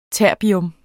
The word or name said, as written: terbium